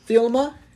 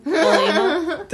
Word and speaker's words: Thelema: I've not said it right this whole fucking two-part episode. Thelema. No, not Thelema. It's just fucking not Scooby-Doo. Thelema. Thelema. There it is.